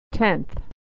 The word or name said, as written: tenth